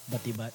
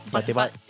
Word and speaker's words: Batibat: I apologize if I mispronounce it, but Batibat? – Batibat. – Batibat, yeah.